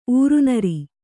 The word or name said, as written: ಉರಿನೀರ್